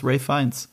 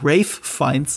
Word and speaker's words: Ralph Fiennes: Ich habe mir übrigens, wie du merkst, antrainiert, weil ich das noch mal im Interview gesehen habe, dass man Ralph Fiennes sagt und nicht Ralph Fiennes. Ich sag mein Leben lang Ralph Fiennes und es ist falsch. Er heißt Ralph Fiennes, das wird anders ausgesprochen, warum auch immer. Da gab es eine Begründung für irgendwas Altenglisches, keine Ahnung.